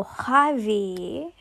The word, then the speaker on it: Javier: And that's how Xavier is kind of like, it comes from quite deep in the throat. And it's almost like an H that it starts with. J's usually start with H in certain parts of Spain. So Javi is like that, but it's spelled J-A-V-I-E-R.